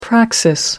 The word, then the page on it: praxis: American English